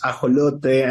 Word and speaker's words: ajolote: In Spanish, it's, you know, I think it sounds nice, right? It's ajolote, and it's an endemic amphibious animal from Mexico.